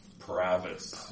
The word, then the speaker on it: Provis: Pravis. Yeah. – William Pravis. – Short, just think short O.